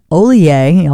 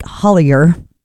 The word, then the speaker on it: Hollier: A new band to our airwaves by the name of John Ollier, although it looks like Hollier, H-O-L-L-I-E-R, John Ollier and The Reverie. He's French roots, thus the pronunciation on that last name of his.